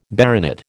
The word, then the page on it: baronet: American English